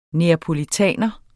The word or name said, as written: napolitaner